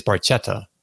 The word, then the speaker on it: Barchetta: My Italian pronunciation, you look at this word and you think it's Barchetta, but no. When there's an H, you don't pronounce the H, and then when there's not an H, you pronounce the H, I guess.